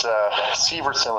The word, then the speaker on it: Severson: It's Severson with the long E.